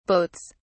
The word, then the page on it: boats: British English